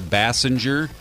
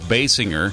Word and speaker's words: Basinger: Kim Basinger, some might call her Basinger. I go with Basinger as far as the pronunciation of her last name.